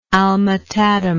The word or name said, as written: alma tadema